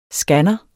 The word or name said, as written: scanner